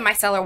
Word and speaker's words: micellar: So the mascara, you have taught me a method to put on the mascara so I don't because I use the microcellular water. – The micellar water.